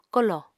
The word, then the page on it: kolò: Apatani